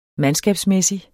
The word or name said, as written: mandskabsmæssig